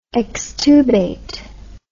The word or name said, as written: extubation